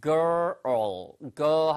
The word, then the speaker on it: girl: 이 발음은 자주 해드리겠지만 걸 G-U-L-L, gull, seagull, seagull 할때 우리가 갈매기라고 할때 그거는 gull 이거고요. 이거는 girl, girl 한 다음에 earl처럼 뒤로 땡기면 돼요. girl, 됐죠?